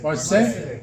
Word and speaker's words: Marseille: How do you say that port in French? Marseille. Thank you.